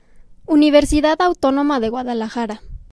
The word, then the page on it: Universidad Autónoma de Guadalajara: Spanish